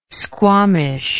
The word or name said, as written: squamish